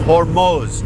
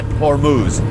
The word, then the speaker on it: Hormuz: By the way, that's the correct pronunciation. It's Hormuz, not Hormuz.